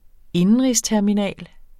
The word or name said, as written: indenrigsterminal